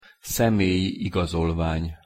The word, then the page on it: szemelyi igazolvany: Hungarian